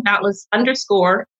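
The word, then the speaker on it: Dontless Couture: And then we're also on Instagram, Dauntless_Couture, and also Twitter. Oh, yes. I should spell Dauntless. We spell it Dontless. So that's Dontless, but we pronounce it Dauntless Couture.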